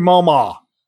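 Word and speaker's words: Malmö: M-A-L-M-O, but the O has the umlauts on front of it. And it's like, that'd be an awe, right? Like Malmo.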